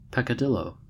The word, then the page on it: peccadillo: American English